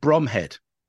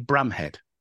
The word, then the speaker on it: Bromhead: Yeah, and he said, you know, and that everyone's pronounced it Bromhead in the film, and they said, lovely, wonderful performance, you know, great to be honoured, blah blah blah. We have to point out it's actually Bramhead.